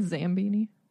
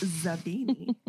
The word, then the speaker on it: Zabini: Slughorn greets them, but particularly Harry and Zabini with extra enthusiasm because he remembers them from the Slug Club meeting on the train. – Don't you mean Zambini? – No, it's Zabini.